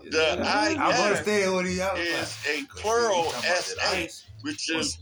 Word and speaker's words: Isis: And when they wake up, they call back Isis, Queen of Heaven and Earth. What's the first syllable of that name? The first syllable, not letter. – Is that ice.